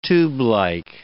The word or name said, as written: tubelike